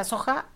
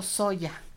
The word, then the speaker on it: soja: Y se pronuncia soja o soya, ¿no? – Que creo que más en el español castellano lo pronuncian soja. Nosotros acá más, soya. – American, pues sí, en América, americanizado, le decimos soya con Y, ajá.